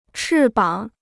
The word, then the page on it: 翅膀: chì bǎng